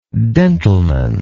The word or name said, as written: dentalman